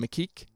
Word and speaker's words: Mikic: And then Alex, how do you say his last name? – Mikic, as Ricky, also like really good, like for the limited moments that he had as well.